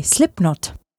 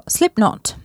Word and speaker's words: Slipknot: Na slipknotech je zajímavé, teda alespoň z fonetického hlediska, že K ve výslovnosti je tiché, takže správně bychom je měli vyslovovat jako slipknot. Máme ale zažité slipknot, tak proč ne?